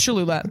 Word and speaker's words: Cholula: Yeah, no, it's Cholula, but it is delicious.